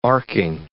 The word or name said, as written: arcing